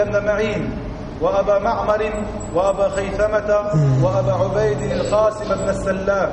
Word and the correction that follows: القاسم: أبو عبيد القاسم معلوم معروف طبعا من هو أبو عبيد، كان أيضا إماما وصاحب كتاب الإيمان وصاحب الغريب المصنف في الحديث.